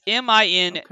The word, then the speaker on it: Mindustry: I was very curious if you were going to pronounce it Mindustry or Mindustry. – I think it's Mindustry. – That makes more sense. – It's Mindustry. So everybody else can make their decision. It's like GIF or JIF.